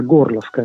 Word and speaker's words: Gorlovka: città di Orlivka, questa è la pronuncia ucraina, Gorlovka in russo, e sostanzialmente mi ha colpito molto, naturalmente, anche per il percorso accademico che ho fatto.